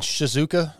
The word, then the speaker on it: Shizuoka: You were born in Shizuoka, Japan? – Yeah.